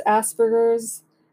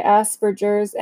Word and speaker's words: Asperger's: But um, okay, first of all, the question that a lot of us have how do you pronounce Asperger's? I know that it's a hard G.